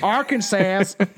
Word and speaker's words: Arkansas: Arkansas? Is that how you say it? – Arkansas.